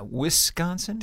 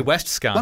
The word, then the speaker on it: Wisconsin: And I think he said Wisconsin right, too. – Did he? Yeah, Wisconsin? – Didn't say Westconsin.